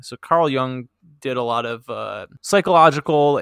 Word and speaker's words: Jung: And then is that how you pronounce it? Jung? – Jung? It is. – Is it? Okay. – Jung.